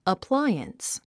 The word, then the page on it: appliance: əpláiəns; アップライアンス